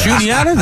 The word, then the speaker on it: Juniata: I pronounced Juniata Juanita, my first telecast. – Juniata, the college? – Well, spelled like Juanita.